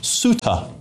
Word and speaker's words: Ceuta: Apparently that place is, James has said here, James H said it's pronounced Ceuta.